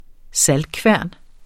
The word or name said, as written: saltkværn